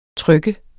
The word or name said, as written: trykke